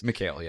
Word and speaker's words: Michel: Depends on how you pronounce it. M-I-C-H-E-L. – Right. – This is in America, we pronounce it Mitchell.